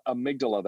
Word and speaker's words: amygdala: In the very back of your brain, near your brain stem, is a little almond-shaped and sized part called the amygdala. That's for those of you who care. That's spelled A-M-Y-G-D-A-L-A.